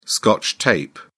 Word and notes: scotch tape: British English

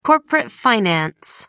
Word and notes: corporate finance: American English

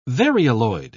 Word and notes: varioloid: British English

